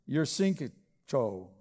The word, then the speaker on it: Yushchenko: They rallied behind a political reformer by the name of Viktor Yushchenko.